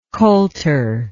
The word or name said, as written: colter